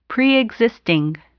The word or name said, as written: preexisting